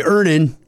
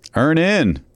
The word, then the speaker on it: Earnin: I'm going to pronounce this properly, Earnin. – Earnin. – E-A-R-N-I-N is pronounced Earnin. I don't know how else you would pronounce it, but they really want to be clear that that's how to pronounce it.